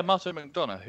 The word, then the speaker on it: Martin McDonagh: Yeah, Martin McDonagh, who also um, directed three three billboards outside Ebbing, Missouri.